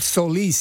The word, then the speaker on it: Solis: Am I pronouncing that right? – It's called Solis.